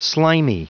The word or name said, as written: slimy